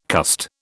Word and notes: cussed: British English

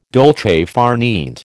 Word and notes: dolce far niente: American English

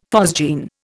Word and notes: phosgene: Indian

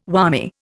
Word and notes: whammy: Indian

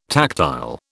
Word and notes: tactile: British English

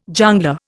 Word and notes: jongleur: Indian

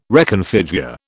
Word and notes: reconfigure: British English

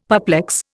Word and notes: perplex: Indian

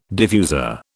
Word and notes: diffuser: British English

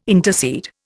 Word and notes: intercede: Indian

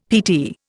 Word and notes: PT: Indian

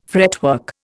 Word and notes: fretwork: Indian

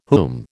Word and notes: whom: American English

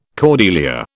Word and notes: cordelier: British English

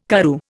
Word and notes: Karoo: Indian

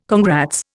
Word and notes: congrats: Indian

